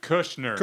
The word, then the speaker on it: Kushner: Kuchner? How do you say that? – David Kushner.